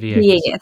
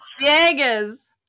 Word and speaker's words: Villegas: Did I say it right? – Villegas. – Villegas. Thank you.